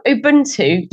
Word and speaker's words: Ubuntu: So tell us about, I'm going to pronounce this wrong, I'm sure, Ubuntu or Ubuntu? – Oh, Ubuntu, yes.